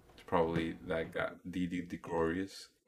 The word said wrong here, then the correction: Didi Gregorius: Didi Gregorius. You got me tongue twisted here. Didi Gregorius. – Didi DeGregorius. – No, Gregorius.